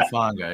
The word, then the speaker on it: Hufanga: I'll never say it right. Hufanga. – Talanoa Hufanga, yeah.